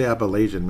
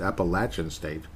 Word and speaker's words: Appalachian: And that is how you say it, apparently. I used to say Appalachian many years ago. It's Appalachian State, which sounds kind of cool.